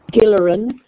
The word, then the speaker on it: Gilleran: Gilleran.